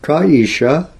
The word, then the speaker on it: Kesha: So those asuras, an incarnation of a Shudra, a blackish tribe, and an incarnation of a hare, not knowing that the word Kesha means Ka Isha. So here is a short A instead of a long A.